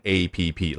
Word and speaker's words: app: 好，那第二个知识点呢，就是 app 它的读音，注意不要念成 a p p，老外听不懂。你要么念 app，要么念 application，它的全称。